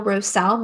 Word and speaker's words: Roessle: Danielle Roessle, my last name is spelled R O E S S L E.